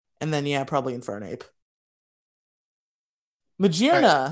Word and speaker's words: Magearna: The whole gimmick of her name is that she is made out of gears. It is Magearna. Say it right.